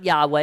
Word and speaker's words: Yahweh: It's four radicals, four letters, a Y, a H, a W, an H. And every time we come to that four radicals in our Old Testament, we pronounce it Yahweh.